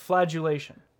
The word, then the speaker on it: flagellation: Flagellation. – What, a D? – It's actually a G, but yeah.